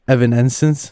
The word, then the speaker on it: Evanescence: How do you say Evanescence? – Evanescence. – Evanescence. – Evanescence. – It's a Evan.